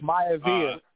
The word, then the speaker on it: Maivia: Maivia.